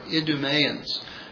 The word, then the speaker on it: Idumea: Lastly, in the scriptures, we see, ironically, that the Herods that reigned during the life of Christ were Idumeans. Now, we pronounce it Idumea, but it's very much like Edom, right, phonetically.